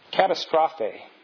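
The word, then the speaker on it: katastrophe (καταστροφή): It's katastrophe is the Greek word. Now, in the Greek, it starts with a K, if you're looking. But in English, we spell it with a C. We start it with a C. And it's catastrophe.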